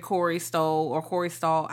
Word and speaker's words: Stoll: First of all, now, I know I've seen that character, Corey Stoll.